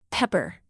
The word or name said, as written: pepper